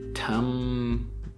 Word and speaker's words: timbre: Music often has timbre. I don't know if I'm really pronouncing it well. Oh, here we go. This is a French word pronounced the French way, timbre. Timbre. The timbre. It's the way that it's spelled right here is T-A-M-B-R.